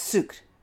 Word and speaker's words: sucre: And she actually corrected my pronunciation of sugar. I was saying sucre, and it's sucre. Just, you stop at the R. There's no E.